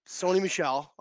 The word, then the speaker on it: Sony Michel: It's Sony Michel. Come on. I, I said Sonny Get Michelle, together. Sony. didn't I? – Sony, Sony like TV. video here. – I, Sony Michel.